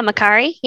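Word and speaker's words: Makkari: Makari? Is that how you pronounce her name? – Yeah, Makari. Yeah.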